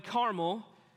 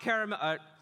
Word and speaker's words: Carmel: Even though we're on Mount Carmel, which, by the way, Carmel is how you pronounce the mountain, not the candy. Okay, it's not Mount Caramel.